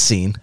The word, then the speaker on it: Sin: It actually is spelled S-I-N, like sin. But in the ancient world, and they're not exactly sure what this means. It definitely doesn't mean the wilderness of sin, like disobedience.